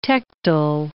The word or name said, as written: tectal